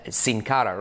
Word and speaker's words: Sin Cara: When he came in, it was like the Office Space moment of everybody coming up to you and saying, hey, you know it's pronounced Sin Cara, right?